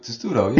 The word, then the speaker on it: Testudo: We'll have Cody Wilcox from SB Nation's Testudo. Did I say it right? – Testudo. – Yeah, there's. Thank you. Testudo. Testudo. There you go.